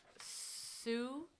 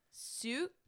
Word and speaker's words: Sioux: Cherokee, Navajo, Sioux. I don't know how to pronounce it. – I think it's like S-I-O-U-X. – Or it's like very simple. It's a lot of the letters are silent.